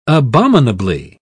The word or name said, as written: abominably